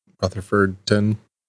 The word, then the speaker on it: Rutherfordton: In Rutherford, Rutherfordton, that's how you say it.